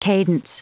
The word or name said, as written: cadence